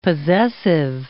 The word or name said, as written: possessive